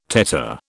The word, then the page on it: tetter: British English